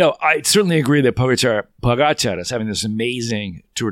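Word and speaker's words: Pogačar: Now, someone told me today actually how to say his name. Pogacar. It's the accent on the second guy, Pogacar.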